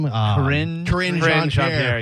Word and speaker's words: Karine Jean-Pierre: Karine Jean-Pierre.